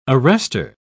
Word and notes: arrester: British English

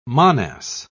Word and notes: monas: British English

